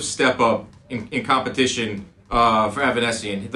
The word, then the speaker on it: Avanesyan: David Avanesyan? – That's correct.